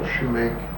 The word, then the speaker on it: sumac: Did you ever hear that called shumac? – No. Sumac, I think. It's S-U-M-M-A-C, I believe. – That's the way it's spelled. But someone was telling me that that's spelled that way but pronounced shumac because shoemakers used to use it for dye.